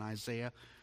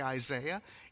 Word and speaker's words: Isaiah: Did you know we mispronounce it when we say Isaiah? It's really Isaiah. And that's how we pronounce those letters, A-I, in every other place in the Bible.